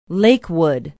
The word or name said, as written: Lakewood